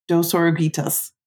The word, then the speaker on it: Dos Oruguitas: Dos Oruguitas.